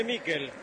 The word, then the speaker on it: Mikel: ¿Cómo le decimos? ¿Michael o Mikel? Nicolás Álvarez, usted que es especialista en nombre, se escribe Mikel, ¿cómo le digo? – Decile Mikel. – Entonces le digo a Mikel, usted me enseña y yo aprendo.